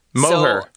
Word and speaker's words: Moher: So, in terms of the Moors, you're pronouncing the Cliffs of Moher wrong. – Moher.